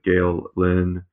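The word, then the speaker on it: Gael Linn: Gail Lynn. I'm sure I'm mispronouncing that. I apologize. – No, you're not. That's perfectly pronounced.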